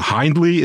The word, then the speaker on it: Hindley: Hindley is de correcte uitspraak, dank u Guy.